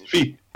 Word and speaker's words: Fils: Is that the correct French pronunciation? Arthur Fields. The 16. – Fils. – Oh, is it Fils? Of course, because it'd be like Monfils.